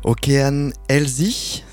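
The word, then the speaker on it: Океан Ельзи: Okean Elzy, c'est comme ça qu'on dit ou pas?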